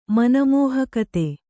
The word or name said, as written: ಮನಮೋಹಕತೆ